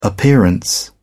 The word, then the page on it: appearance: British English